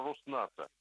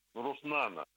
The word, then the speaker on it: Роснано: Я уже не вспоминаю про Роснато, Роснано. Понимаете, тоже руководитель. – Да, вы почти правильно назвали Роснато.